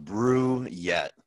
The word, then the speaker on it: Brouillette: So I've been told at least it's pronounced Brouillette.